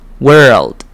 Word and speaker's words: world: 所以记得你发 world 的时候，那个 L 你的舌头要上扬顶到你的牙齿，所以这样子的话你的发音才会清楚。 world， world。